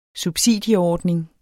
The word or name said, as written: subsidieordning